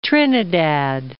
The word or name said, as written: Trinidad